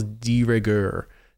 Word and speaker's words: de rigueur: It almost feels de rigueur at this point. Isn't that how you say it? No, it's not how you say it. – It is. Yeah.